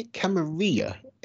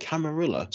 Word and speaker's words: Camarilla: I say Camarilla because I come from a part of the country where there's a lot of Spanish speakers. And so I see a Spanish word like Camarilla and it's like, okay, it's pronounced like tortilla.